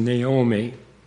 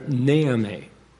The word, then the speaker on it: Naomi: They called her Naomi, with the emphasis on the first syllable, not on the second syllable.